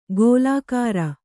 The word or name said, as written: ಗೋಲಾಕಾರ